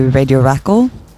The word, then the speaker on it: Radio Rakel: In Norwegian, I'd say Radio Rakel, Radio Rakel, just like in one word.